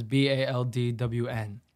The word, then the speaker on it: Baldwn: My artist name is Baldwn.